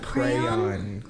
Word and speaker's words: crayon: There's no silent letter in crayon.